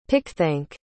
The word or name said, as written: pickthank